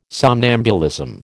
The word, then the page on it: somnambulism: American English